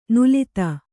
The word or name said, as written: ನುಲಿತ